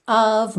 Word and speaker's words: of: State of mind. Of, OF, sempre com som de AV, não é OV, é AV.